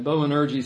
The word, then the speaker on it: Boanerges: And Boanerges sounds like a great, you know, really strange word. It's where we get our word energy from. Boanerges. They didn't pronounce the G the way we would, but sons of thunder came as a nickname, but it was a nickname that reminded them of their temperament.